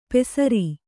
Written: ಪೆಸರೀ

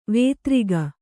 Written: ವಿದಾರಕ

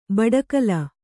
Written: ಬಡಕಲು

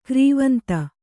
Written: ಗರ್ವಾಂಧ

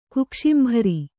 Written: ಕುಕ್ಷಿಂಭರಿ

